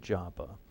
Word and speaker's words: Joppa: I'm going to go with Joppa. And, yeah, because the long O in Greek is an omega, and that's an omicron.